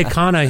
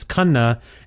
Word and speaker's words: Khanna: Although I say Kana here in Canada because I used to go by Canna and I didn't like Kiana. – It. And then no one could pronounce it. And then I know the proper pronunciation is Kanna, and I've sort of assimilated it to Kana because I think it's easier for the whites to say Kana.